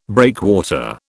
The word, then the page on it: breakwater: British English